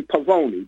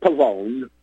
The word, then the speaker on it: Pavone: In Italian, you'd probably say Pavone, but he pronounced it Pavone. Frank Pavone.